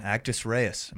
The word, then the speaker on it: Actus Reus: Today I have with me three members of the band, Actus Reus. Am I saying that right? – Yeah.